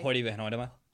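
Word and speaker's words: Hoyte van Hoytema: Hoyt von Hoytema.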